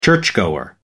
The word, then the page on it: churchgoer: American English